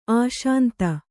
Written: ಅಶಾಂತ

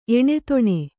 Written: ಎಣೆತೊಣೆ

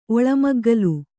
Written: ಒಳಮಗ್ಗಲು

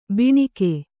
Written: ಬಣಗ

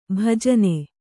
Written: ಭಜನೆ

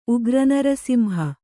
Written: ಉಗ್ರನರಸಿಂಹ

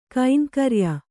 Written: ಕೈಂಕರ್ಯ

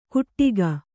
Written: ಕುಟ್ಟಿಗ

